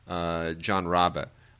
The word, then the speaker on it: Rabe: John Rabe, or Rabe, is how it's spelled, R-A-B-E.